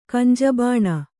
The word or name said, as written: ಕಂಜಬಾಣ